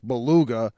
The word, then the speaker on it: beluga: So, Hank, put that belugia in the library for words mispronounced. It's beluga.